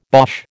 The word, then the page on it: Boche: American English